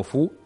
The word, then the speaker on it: Foo: Eh, Fu Fighter significa, eh, en teoría, feu, es fuego en francés, que es una mala pronunciación, y se dice como Fu, y Fighter, pues es un caza y significa caza de fuego o algo así.